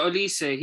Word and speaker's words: Olise: How do I say his name? Is it Olise? Oh, yeah, Lise. – Yeah, Olise, he was at Reading.